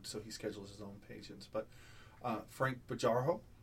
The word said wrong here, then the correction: Bejarano: and I hope I'm pronouncing his name right. I'm still learning a lot of my pronunciations. – Well, I'll intervene on that. It's actually Frank Bejarano. – Bejarano.